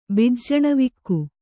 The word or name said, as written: ಬಿಜ್ಜಣವಿಕ್ಕು